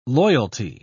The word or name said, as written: loyalty